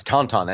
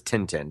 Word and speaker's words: Tintin: It's Tonton, and everybody in America probably says Tintin because that's how it looks when you spell it. And we never – we don't live with Tintin. At most, we might have looked at the comic books. We haven't seen the TV shows. People don't talk about it, so nobody ever says the name. So we never hear it pronounced Tonton.